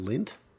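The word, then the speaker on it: Lindt: No, lint, like the chocolate. – Oh, like okay, lint, yeah. – Made out of lint. – Lindita. – Oh, sorry, lindit. – I don't think that's how you say it.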